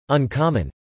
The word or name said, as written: uncommon